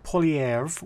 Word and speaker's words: Poilievre: Pierre Poilievre, which I can't pronounce properly.